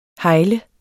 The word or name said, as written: hegle